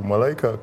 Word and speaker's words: Malaika: So I actually wanted to So my Malaika is spelled M-A-L-I-K-A, right? But the way it's supposed to be pronounced is M-A-L-A-I-K-A.